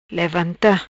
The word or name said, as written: Levanta